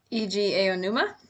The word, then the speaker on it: Eiji Aonuma: It's directed by Eiji Aonuma.